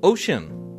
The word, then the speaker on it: Ocyn: He's launched a brand new musical venture called Ocean. Now it's spelled O C Y N, and he's releasing what he's calling what do you call it?